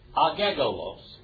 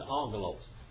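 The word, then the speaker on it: angelos (ἄγγελος): Now, if you pronounced every letter in there, what would it be? Agegolos. But that's not what it says, angelos.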